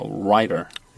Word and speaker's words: writer: 이것도 발음 좀 주의하세요. 이것도 거의 그 예외 없이 이 사람들이 T를 거의 날려서 발음합니다. writer. – writer. – writer. – writer. – writer. – writer. – 그러니까 T는 단어 중간에 있을 때는 약간 D 혹은 리을 발음합니다.